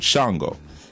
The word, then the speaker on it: Sango: Like, you know, Orisha, a lot of times we'll see it as O-R-I-S-H-A. But it's actually O-R-I-S-A. The S is a sh. So even with Shango, it's really S-A-N-G-O, not S-H-A-N-G-O. In some cultures, you'll see it as C-H-A-N-G-O.